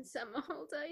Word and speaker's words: Somerhalder: Ian Somerhalder.